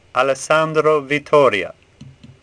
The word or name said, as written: Vittoria